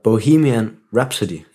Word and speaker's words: Bohemian Rhapsody: Den, der hedder Bohemian Rhapsody. – Ja. – Og du har været lidt efter mig med, hvordan jeg udtaler det. Ja. Det udtales også meget underligt. Du gjorde det helt rigtigt ja.